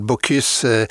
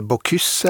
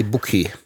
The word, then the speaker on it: Bocuse: I forkant av eh, årets Michelin-runde så har det vakt internasjonal debatt, så vidt jeg skjønner, at restauranten Paul Bocuse mista ei stjerne etter 55 år. Og forresten, jeg hører det litt annerledes, forskjellig. Hvordan uttales dette? Bocuse eller Bocuse? Du må for all del ikke si Buky.